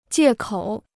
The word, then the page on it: 借口: jiè kǒu